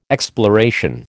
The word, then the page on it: exploration: American English